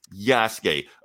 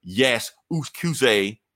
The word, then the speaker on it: Yasuke: And first off, it's Yasuke. I'm tired of people butchering Japanese pronunciations. It's not Yakuza or Yasukuze. It's Yasuke. Just like Street Fighter, Ryu from Street Fighter is not Ryu from Street Fighter.